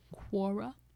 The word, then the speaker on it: Quora: Quora? I think that's how you pronounce it. – Legends of Quora? – No. it's spelled Q-U-O-R-A.